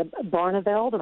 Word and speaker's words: Barneveld: Right, yes, Verona, and don't forget the Barneveld. Am I saying that correctly? – Yes.